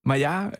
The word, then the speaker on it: Maillard: Det hedder en Maja-reaktion. – Ja. Maja øh, er faktisk øh, efternavnet på en fransk kemiker, som øh, levede i starten af forrige århundrede altså i starten af 1900-tallet,